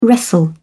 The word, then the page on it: wrestle: British English